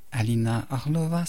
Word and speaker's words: Alina Orlova: Petit truc en passant, si vous aimez ce que vous avez entendu, vous allez sur YouTube, vous cherchez Alina Orlova, ça s'écrit comme ça se prononce.